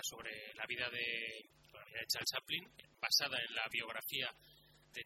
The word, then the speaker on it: Chaplin: Es Chaplin, la película de 1992, dirigida por Richard Attenborough.